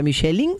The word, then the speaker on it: Michelin: Es francés, tiene por el Michelin. – Nada más que Michelin estamos acostumbrados... – Nosotros decimos las Michelin. – Michelin. – La Michelin. – De qué estamos hablando. – Es Michelin. Pero es Michelin, ella lo pronuncia bien en francés.